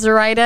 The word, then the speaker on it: Zoraida: That's Z-O underscore Rye, R-Y-E underscore D-U-H. So Zoe Ryda. – Zoe Ryda. – There you go. And I did that phonetically because my name is Zoraida, and I get asked a lot, like, how do you say your name? And I'm like, okay, what is the simplest, silliest way to write it? So Zoe Ryda.